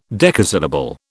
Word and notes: decasyllable: British English

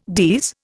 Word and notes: DES: Indian

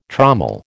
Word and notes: trommel: American English

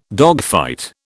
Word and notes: dogfight: British English

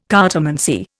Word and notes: cartomancy: Indian

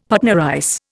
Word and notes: Patna rice: Indian

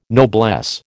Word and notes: noblesse: American English

